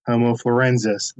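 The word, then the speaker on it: Homo floresiensis: Talking about Homo florenziensis or Florenzi, how do you pronounce it? Florenziensis? – Florenzia thickapicus? – Homo florenziensis. That's it.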